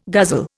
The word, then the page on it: guzzle: Indian